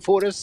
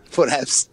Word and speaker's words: Foraeus: Men den här längdchefen Daniel Foraeus, hur säger man ens hans namn? Foraeus? – Det var ett väldigt bra uttal. Säg det en gång till. – Foraeus.